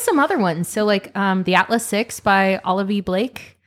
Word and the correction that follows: Olivie: Olivia? – No. – Really? Am I high? – Olivie.